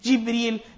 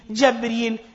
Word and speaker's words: Jibreel: Ibn al-Jawzi, in tafsir of Ibn al-Jawzi, has mentioned 11 different ways, different lughat of pronouncing and saying the name Jibreel. Jibreel, Jabreel, Jibra'il, Jabra'il, and what we say as well, what we know the name to be, Jibra'il.